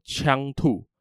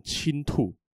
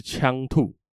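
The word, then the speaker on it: 锖兔: 这个人叫做枪兔，枪这个字呢是一个金再一个青，而很多人会念错，会念成青兔或者是金兔，因为很像青鱼的青嘛，然后又反正就是有个青，大家就有边读边没边念中间这样，所以就念成青兔。或者是金兔，但其实正确的念法是枪兔。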